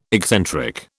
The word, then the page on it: excentric: British English